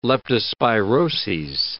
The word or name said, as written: leptospiroses